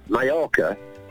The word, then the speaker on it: Mallorca: Two L's in Spanish. – Yeah. – You pronounce yeah. – Right. – Yeah. Like the Y sound. So Mallorca is just spelled double L.